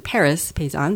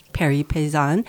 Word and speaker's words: Paris Paysan: And since you lived here, you launched a very successful blog called Paris, as an American would pronounce it, Paris Paysan, but Paris Paysan.